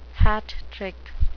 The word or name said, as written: hat trick